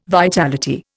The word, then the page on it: vitality: Indian